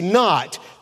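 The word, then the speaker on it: knot: kind of like our K on the word not. We don't say cannot, we say not.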